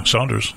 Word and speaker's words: Saunders: Is that how you say it? Yeah, Saunders. That's right.